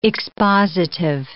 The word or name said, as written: expositive